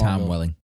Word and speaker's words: Tom Welling: Tom Welling.